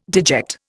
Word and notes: deject: Indian

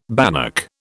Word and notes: bannock: British English

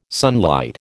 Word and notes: sunlight: American English

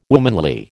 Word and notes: womanly: American English

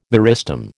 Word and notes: meristem: American English

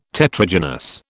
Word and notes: Tetragynous: British English

